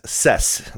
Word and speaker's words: Sess: And basically just it comes out as Sess, like S-E-S-S is how it would be pronounced.